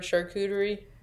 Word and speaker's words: charcuterie: And also, I don't know, do you guys ever have a hard time pronouncing the word charcuterie? – Charcuterie.